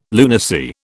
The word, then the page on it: lunacy: British English